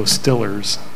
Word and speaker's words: Steelers: They go Stillers. They don't. That's the correct pronunciation of Steelers in Pittsburgh.